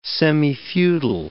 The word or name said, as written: semifeudal